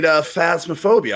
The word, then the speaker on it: Phasmophobia: You played Phasmophobia.